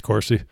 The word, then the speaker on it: Corsi: It's Corsi.